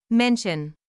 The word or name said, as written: mention